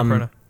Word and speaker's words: Annapurna: But with this, with MGM and Annapurna, I think I'm saying that right. – Yeah, Annapurna.